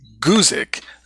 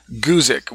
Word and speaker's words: Guzik: I think his name is pronounced Guzik, Guzik, with a kind of a long U there. I've heard some people say Guzik, but I think it's Guzik.